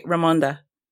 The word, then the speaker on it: Ramonda: Sorry, go ahead. I know I pronounced that. – Okay, Ramonda. – Thank you. Yes, I was like, I know I'm mispronouncing that.